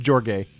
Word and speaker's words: Jorge: So, George. – Yeah, Jorge. I think it's pronounced Jorge, Eli, if you don't mind me correcting you.